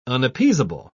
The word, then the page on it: unappeasable: British English